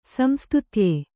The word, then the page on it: ಸಂಸ್ತುತ್ಯ: samstutya